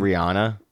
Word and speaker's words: Rihanna: Rihanna gives fans a behind the scenes. – Is it Rihanna? – Yes.